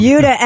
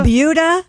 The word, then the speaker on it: Buda: It's called the Buda. – Buda. – Skip over. – Buda. Sounds like Peter Wolf. – It's spelled B-U-D-A, and I looked it up. It is pronounced like beauty.